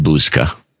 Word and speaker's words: Buszka: It really Rory Buzka, B-U-S-Z-K-A, which has to be pronounced Buzka.